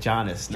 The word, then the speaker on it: Giannis: Well, there's Giannis who... that's not how you say it. Like Giannis.